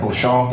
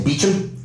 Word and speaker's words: bouchon: British people, Irish people, anyone, any type of UK colonial people insist on pronouncing French words improperly. They don't say bouchon, they say beechum,